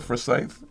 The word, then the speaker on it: Forsyth: Forsyth? – Yeah, Forsyth.